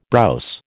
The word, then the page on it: browse: American English